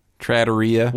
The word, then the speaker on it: trattoria: Trattoria? How do you pronounce that? – Trattoria.